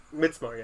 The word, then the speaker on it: Midsommar: Midsommar, yeah, it's like a Swedish pronunciation. – Midsommar.